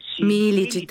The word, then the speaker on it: Миличи: Миличи ли точното произношение? Как е? – Миличи, точно така, да.